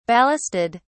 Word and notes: ballasted: British English

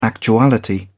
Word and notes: actuality: British English

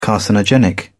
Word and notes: carcinogenic: British English

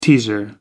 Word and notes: teaser: American English

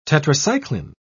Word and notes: tetracycline: British English

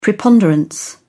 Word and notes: preponderance: British English

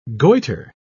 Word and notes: goiter: British English

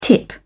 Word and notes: tip: British English

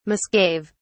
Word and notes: misgave: British English

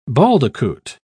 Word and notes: baldicoot: British English

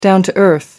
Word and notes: down-to-earth: American English

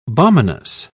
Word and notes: balminess: British English

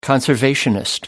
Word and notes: conservationist: British English

